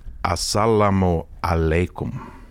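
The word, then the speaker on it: assalamu aleikum (السلام عليكم): Och till busschauffören där så sa jag då salam aleikum. – I Tunisien så eller? – Ja, i Tunisien. – Ja, jag förstår inte folk i Tunisien. – Och så stannade han upp med så han, det heter assalamu aleikum. Okay. Så jag fick lära mig, jag fick be om ursäkt och uttala det riktigt då.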